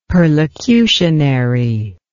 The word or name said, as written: perlocutionary